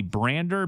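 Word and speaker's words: Brandr: I believe it's the Brander, B-R-A-N-D-R. I believe that's how it's pronounced.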